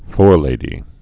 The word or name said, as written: forelady